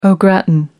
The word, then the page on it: au gratin: American English